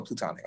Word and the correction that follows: platonic: I don't know why I keep saying plutonic. – I always pronounce it platonic, like P-A-L, but. – Plato. From Plato.